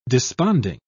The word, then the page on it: desponding: British English